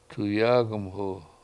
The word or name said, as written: tuyAAg1mhu